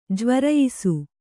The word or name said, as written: ಜ್ವರಯಿಸು